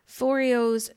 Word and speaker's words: Foreo: And then the other example that we had is, I don't really know if I'm going to pronounce the brand name all that correctly, but Foreo. Is that how you would say it? – Yeah, Foreo, yeah. – Foreo's Luna Fofo.